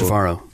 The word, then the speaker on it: Trevorrow: Is it Trevorrow or Trevorrow? – It's Trevorrow.